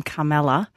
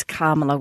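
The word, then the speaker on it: Kamala: I actually pronounced her name Carmela, and it is Carmela, which somebody pointed out to me.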